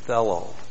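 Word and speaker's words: thelo: Whoever wishes, and wishes is the present active subjunctive of the verb fellow. T-H-E-L, long O, fellow.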